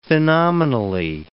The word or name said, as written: phenomenally